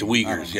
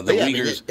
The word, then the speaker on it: Uyghurs: I believe it's UGH. What is it? How do you know how to spell it? UGH. – Something. – It's Uyghurs, yeah. Uyghurs.